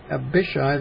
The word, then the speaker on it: Abishai: At once Abishai, I'm going to pronounce that I, not A, I think, the A-I at the end of a word is not accented or accented. Abishai, the son of Zeruiah, came to his help and struck the Philistine down and put him to death.